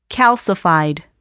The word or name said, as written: calcified